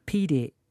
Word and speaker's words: pire: Pire is loan or account. So P-I-R-E, if you doubt my pronunciation, pire is a loan or account.